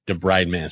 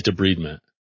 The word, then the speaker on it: debridement: It's not debridement, it's debridement. It's always debridement. 100 bajillion % of the time, except for in dentistry. We kind of make up our own thing. – How to pronounce debridement, YouTube. – Yeah, see if you can play it. See? – Damn, it is debridement.